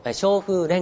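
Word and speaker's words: 正風: で元々は、和歌よりも芸術性は低いと言われてましたけども、そのレンガを和歌のレベルまで芸術性を高めたものを正しい風の文化と書いて松風文化と、松風レンガと言います。正しい風のレンガですね、松風レンガと言います、はい、でその松風レンガを集めたのがあのここに書いてます葬儀ですね、葬儀の新撰、筑波集です。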